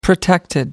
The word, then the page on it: protected: British English